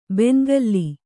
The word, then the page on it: ಬೆಣ್ಣೆಗಲ್ಲು: beṇṇegallu